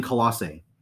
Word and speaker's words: Colossae: But there's two nearby cities, ancient cities called, and I probably am going to destroy these names, but it's Hierapolis and Colossae. – You said it right.